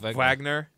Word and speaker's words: Wagner: So, and then on the other side, the guy who kind of upped his stock a little bit was Wagner. – Wagner. – Is that how? That's how. – It's the W. The V. It's a V.